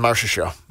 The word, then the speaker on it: Marchessault: Mark, how do you say it? Mark Chusola, Jonathan Marchessault. – Marchessault, yeah.